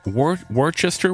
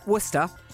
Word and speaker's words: Worcester: Worcester? – Worcester. – Is that like your sauce? Worcestershire sauce? – Worcester sauce. – No, it's Worcestershire. – No, it's Worcester sauce. – But why do you spell it like Worcestershire sauce? Anyway, Worcester. – Yeah. – With loads of unnecessary letters.